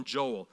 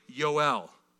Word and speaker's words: Yoel: We would call him Joel. Pronounced accurately, Yoel.